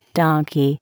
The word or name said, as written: donkey